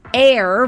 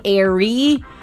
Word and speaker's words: Aire: The name is Air, but it's spelled A-I-R-E. There was some confusion whether it's going to be Airy. Is it pronounced Airy? What is it? But she clarified that it's pronounced A-I-R, like air. – So why not just spell it A-I-R? – I don't know.